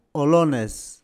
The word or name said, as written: Olones